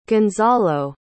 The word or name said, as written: gonzalo